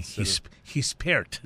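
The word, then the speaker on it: Gispert: I was calling it Gespert, or Gespert, but it's actually Gespert. – Gespert is how you say it. Gespert, and you pronounce the T on the end.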